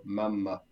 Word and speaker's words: mamma: double M in the middle, so mamma. – Correct. And careful here because it's il papà with an accent on the last A, but it's la mamma.